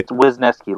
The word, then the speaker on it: Wisniewski: Wisniewski, like Wiz, like a wizard, Ness, like that that little lockup in Scotland, and key, like the thing that opens the door.